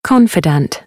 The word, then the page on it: confidant: British English